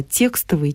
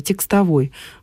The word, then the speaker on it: текстовый: Текстовой — устаревший вариант, и сегодня он нормативным не является. Сегодня ударение должно все-таки ставиться только на первый слог. Текстовый.